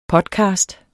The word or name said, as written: podcast